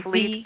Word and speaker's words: Vliet: How do you say her last name? – Elizabeth Lee Vliet. It's Fleet, but Vliet. It's V-L-I-E-T.